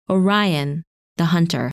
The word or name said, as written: Orion